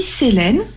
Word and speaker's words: Selene: Alors je dis Selene, ça s'écrit S-E-L-E-N-E, mais les Américains le prononcent Selene.